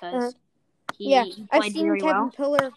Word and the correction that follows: Pillar: He, he's a former San Francisco it's, Giant. It's Pilar. – Oh, P- pronouncing his name mm-hmm. wrong. Sorry, Pilar.